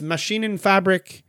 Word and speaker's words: Maschinenfabrik: He went to work for the Maschinenfabrik Maschinenfabrik Daniel Straub. Now, I butchered it. I don't know why I went like samurai on that, but like it's Maschinen and Fabrik Daniel Straub, where he worked on mills and turbines.